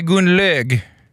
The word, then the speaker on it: Gunlög: Gunlöv, för det är höst och det är löv på marken. – Nej, förlåt, det är Gunlög. Jag beklagar, mitt dåliga uttal.